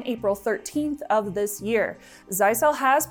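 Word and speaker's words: Zyxel: If you own Zycel networking equipment, and yes, that is how it's pronounced, they actually have a music video on their own YouTube channel dedicated to the pronunciation.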